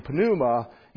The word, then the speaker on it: pneuma: So we've turned the P in pneuma into a silent letter, right?